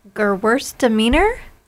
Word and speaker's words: Gewürztraminer: It's Gewürztraminer. – Yes, you said it. You said it very nice. Yeah, you said it nice. It's in the same category. – Okay. – And Gewürztraminer, you probably said it better than I did, also has levels of dry to sweet.